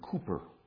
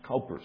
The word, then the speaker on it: Cowper: William Cowper, often wrongly pronounced Cowper, C-O-W-P-E-R, William Cowper, great, great hymn writer and regarded, incidentally, as one of the greatest poets of the 1700s.